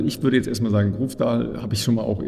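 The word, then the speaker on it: Grøvdal: Es wird auch ein sehr spannendes Debüt geben, nämlich mit von einer norwegischen Athletin, nämlich Caroline, ich glaube, Grofdal spricht man so aus, richtig? – Grofdal, ja.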